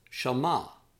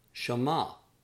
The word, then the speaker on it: shama: Number 32 means to hear or to obey, and that is pronounced shama, shama.